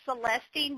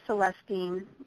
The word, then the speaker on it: celestine: I call it celestine, but I guess some people call it celestite. Is that how you pronounce it? – Celestite, yeah, or celestine, either way.